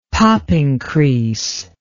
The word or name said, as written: popping crease